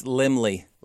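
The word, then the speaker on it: Laemmle: It was founded in 1919 by Carl Lemel. – Actually, I'm going to help you pronounce it. It's Lemley.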